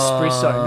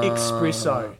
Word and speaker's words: espresso: It's espresso, not expresso. – And people get fired up, are they? – They do, actually. – Oh, really? – So you need to get pronunciation right.